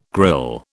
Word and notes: grille: British English

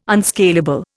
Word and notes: unscalable: Indian

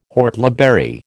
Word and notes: whortleberry: American English

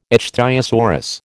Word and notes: ichthyosaurus: American English

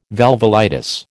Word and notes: valvulitis: American English

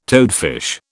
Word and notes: toadfish: British English

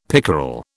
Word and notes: pickerel: British English

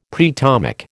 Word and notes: preatomic: American English

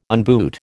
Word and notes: unboot: American English

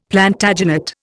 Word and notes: Plantagenet: Indian

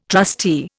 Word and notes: trustee: Indian

